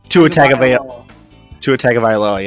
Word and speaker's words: Tua Tagovailoa: It's Tua Tagovailoa, yes.